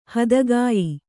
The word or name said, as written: ಹತ್ತಿಕಾಯಿ